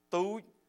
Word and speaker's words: Touch: Now, my name is spelled Touch, and the last name is K-E-O, and most people call me Touch Keel, but I got to tell you how you say it in Cambodia, okay? It's Tu Gao.